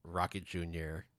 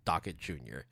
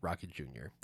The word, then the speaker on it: Rocket Jr.: and you still chose to mispronounce the name of Rocket Jr. by calling it Docket Jr. It might be because you're looking at your docket, but it, for some reason, just kept on like hammering into your head, it's Docket Jr. It's Docket Jr. And then after a while, it's like, no, it's actually Rocket Jr. There were other mispronunciations and misspellings of other artists and song names, but I'll leave it at that, just at that.